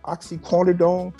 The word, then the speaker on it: oxycodone: According to TMZ, officers claim that they found 31 oxycodone pill. Am I saying it right? – Yeah, oxycortisone. Codeine, yeah. – Oxycodone.